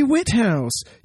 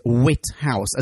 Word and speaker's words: Whithouse: Did you say his name is Whithouse, as in the House of Wit? – Yes. – Wit. – With an H.